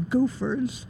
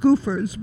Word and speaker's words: Gophers: So not only were there gangs, there was the Goofers, pronounced Goofers, but spelled Gophers.